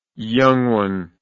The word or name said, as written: young one